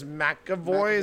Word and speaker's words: McAvoy: Yeah, the James McAvoy is how you say it.